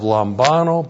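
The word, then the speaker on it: lambano (λαμβάνω): This is the present active indicative of lambano plus the prepositional phrase apa plus the genitive singular of autos. Lambano. L-A-M-B-A-N, long O, lambano. Lambano means to receive.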